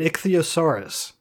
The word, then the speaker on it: ichthyosaurus: In Somerset, there was a family who discovered a fossil of an ichthyosaurus.